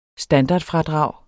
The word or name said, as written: standardfradrag